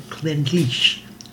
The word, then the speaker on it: Clynelish: It's spelled C-L-Y-N-E-L-I-S-H. Looks like Glenlish, but they said Glenlish, so we'll go with that.